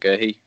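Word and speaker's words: Guéhi: We'll mention in the same bracket Mark Gerhey. Is that how you say it? – Yeah.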